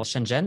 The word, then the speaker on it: Shenzhen: Genau, Shenzhen, ist das so richtig ausgesprochen? Ja.